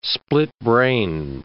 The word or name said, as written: split-brain